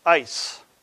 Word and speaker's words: eis: E-I-S. Pronounced ice.